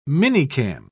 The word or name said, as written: minicam